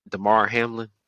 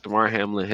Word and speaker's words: Damar Hamlin: I think before we start, we should definitely start with prayers out to Damar Hamlin. Did I pronounce that correctly? Pretty sure I yeah. – did. Damar Hamlin, his family, really crazy situation that happened on the field.